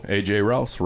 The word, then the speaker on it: Rouse: Yeah, AJ Rouse rhymes with house.